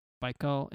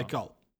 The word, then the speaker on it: Baikal: Baikal in Russia. I don't know. I said it wrong. – Baikal.